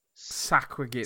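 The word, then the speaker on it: Sahuagin: Saquagin. – Saquagin.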